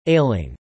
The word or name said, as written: ailing